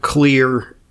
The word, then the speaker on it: Xlear: It's spelled X-L-E-A-R. Clear, well, it doesn't sound like it should be pronounced clear, but it's, you know, the word xylophone, the X becomes a Z, so X's are kind of weird.